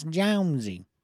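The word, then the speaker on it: Johnse: According to accounts, some people call him Jonesy, some people call him Johnsy. – Oh, got a strange name. So don't at me if I'm not pronouncing this correctly. – Oh, my great-great uncle, you son of a bitch, and it's Jonesy.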